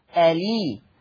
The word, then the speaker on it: الإسلام: هنشيل الهمزة وناخد الكسرة نحطها على اللام، فانطق اللام لام إيه؟ لام مكسورة، هكذا ألي ألي. نلاحظ سويا ألي ألي، خلاص شلت الهمزة وحطيت الكسرة على اللام ألي.